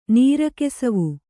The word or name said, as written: ನೀರಕೆಸವು